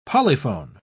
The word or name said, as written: polyphone